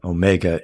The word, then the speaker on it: Omega: Well, Omicron is O micron, like it's a micro O is Omicron, and the Omega is the mega O, the last, and it's a long O.